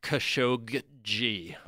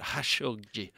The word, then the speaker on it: Khashoggi: NPR was calling Khashoggi. Which just mixing it all up. But Khashoggi. As you were saying, Miles, we could just call him Khashoggi, the one who put the satin on your panties.